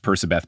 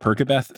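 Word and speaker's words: Percabeth: Yes, because I think when you realize like the whole Percibeth, which is confirmed pronounced Percibeth, because in the roundtable that we did with Rick and Becky, he pronounced it Percibeth, not Perkabeth. So anyone who does Perkabeth, you are incorrect.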